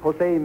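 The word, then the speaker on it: José: We got acquainted with him, and his name is Jose Melet. – Jose? J-O-S-E? – Yes, sir. In Spanish, the J is pronounced like an H.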